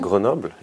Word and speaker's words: Grenoble: They talk about the slightly the first Always Believe moment that I want to talk about is Grenoble. – Mm-hmm. – Is that okay for pronunciation? – Yeah, Grenoble. Yeah. – It's okay. – Yeah, good.